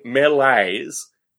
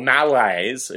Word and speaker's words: melees: My thrill about using the term melees is that the plural of melee and the word melees or malaise, if I wanted to like mispronounce it in order to emphasize the point,